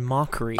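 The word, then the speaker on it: Mochrie: And also just a person, I don't know if our listeners have heard of Colin Motry. – I believe it's pronounced Colin Mockery.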